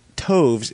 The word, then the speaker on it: toves: The I in Slithy is long, as in writhe, and Toves is pronounced as to rhyme with groves.